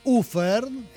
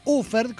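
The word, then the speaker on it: Ofärd: Así es, señores, y seguimos de conmemoración también, porque aquí Offard, pronúnciese Ufard, porque se escribe O-F-A-R-D sobre la A, una diéresis, pero se pronuncia Ufard, como me pone bien aquí Sergio Fernández Ritnikov.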